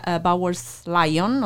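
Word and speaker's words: Bowes-Lyon: Sì, sì, praticamente sono state all'età di 15-22 anni, quindi nel 1923, queste due sorelle, Nerissa e Catherine Bowers-Lyon, ho detto giusto? – Sì, sì.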